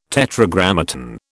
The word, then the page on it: Tetragrammaton: British English